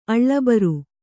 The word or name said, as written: ಅಳ್ಳಬರು